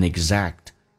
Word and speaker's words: exact: Your life can never be an exact. Exact 在美式发音，那个个是不用发音的，因为后面又是接一个辅音，两辅音在一起就用发前面一个，气流阻塞一下就可以叫 exact。